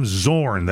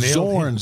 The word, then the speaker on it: Zorn: And I want to make sure I say this right, Jim Zorn. That's with a Z, no relation to yours truly. But you nailed Zorn, Z-O-R-N. – That's correct.